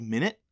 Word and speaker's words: Minit: There's this amazing game called Minute. Have you ever heard of it? – No. – It's spelled M-I-N-I-T.